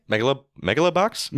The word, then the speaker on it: Megalobox: I only watched Megalobox 2. I'm sorry, the first season of Mega, how do you say it? – Megalobox? – Megalobox, yes.